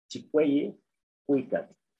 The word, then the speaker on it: Chicueyicuicatl: En náhuatl se pronuncia, es muy fácil, es Chicueye Cuicat.